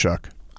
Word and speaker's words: Shough: Yeah, S-H-O-U-G-H? – Yeah, I think it's pronounced Chuck.